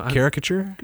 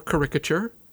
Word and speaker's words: caricature: You pronounce it the way I would. So caricature? – Yeah, or caricature. Doesn't matter.